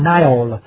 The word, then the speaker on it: Nile: Ra người Việt Nam mình hồi xưa quen đọc tiếng Pháp đọc là sông Nin, nhưng mà ở Anh, nếu em lớn ở Mỹ các em sẽ nghe là người Mỹ đó là sông Nile đó, được viết là N-I-L-E, tiếng Pháp là N-I-L mà phải đọc là Le Nil.